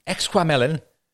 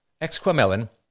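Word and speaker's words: Exquemelin: All right, I looked up how to pronounce this, Exquamelin? No, it's Exquamelin,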